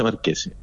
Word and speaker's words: Marchés: Es que es como el Luquetti, la CH suena sí. Como K. – Ya. – Entonces Marqués. Se escribe Marchés y se pronuncia Marqués.